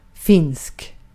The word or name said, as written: finsk